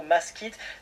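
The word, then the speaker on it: Mask'it: N'hésitez pas à vous renseigner sur les réseaux sociaux Maskit. Ça s'écrit M-A-S-K apostrophe IT. Hein, c'est bien dit.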